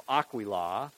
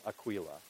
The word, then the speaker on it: Aquila: It's the emphasis on the first ah, so Aquila, or you could say Aquila.